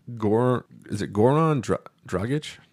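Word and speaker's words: Goran Dragić: Goran Dragic? – That sounds like the right pronunciation.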